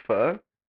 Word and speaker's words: pho: Do you know it's pronounced pho? – I just said it.